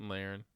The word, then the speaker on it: Lahren: Lahren, yeah.